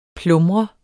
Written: PLUMRES

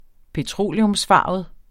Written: petroleumsfarvet

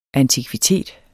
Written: antikvitet

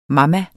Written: mama

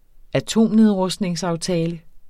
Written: atomnedrustningsaftale

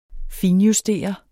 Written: finjustere

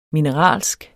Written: mineralsk

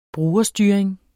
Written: brugerstyring

